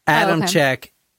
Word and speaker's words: Adamcik: Now, Torey, T-O-R-E-Y, is the guy. Adamcik is actually A-D-A-M-C-I-K. It's like the H is silent or something. – There's no H.